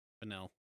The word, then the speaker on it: Fennell: I did actually mispronounce her name. It is Emerald Fennell. – Fennell. – Yeah. Fennell.